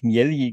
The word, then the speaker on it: Miele: Äh, der wird Miele geschrieben wie die Waschmaschine, aber äh, spricht sich, also sein Name, so sagte er uns, ähm, kommt aus dem Italienischen und deswegen äh, ja, wird er eigentlich Mieli genannt.